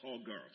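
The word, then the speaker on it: sogar (סגר): The word translated close here in Hebrew means sagar, pronounced sogar. Somebody said sogar.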